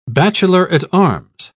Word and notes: bachelor-at-arms: British English

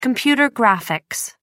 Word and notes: computer graphics: American English

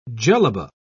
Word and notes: jellaba: British English

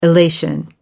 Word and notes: elation: American English

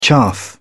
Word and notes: chaff: British English

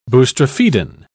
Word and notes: boustrophedon: British English

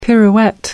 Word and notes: pirouette: American English

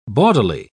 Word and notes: bawdily: British English

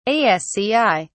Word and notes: asci: British English

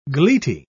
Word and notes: gleety: British English

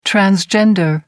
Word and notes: transgender: American English

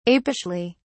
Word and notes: apishly: British English

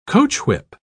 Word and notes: coachwhip: British English

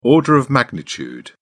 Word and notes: order of magnitude: British English

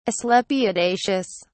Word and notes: asclepiadaceous: British English

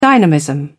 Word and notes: dynamism: British English

